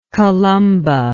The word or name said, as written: columban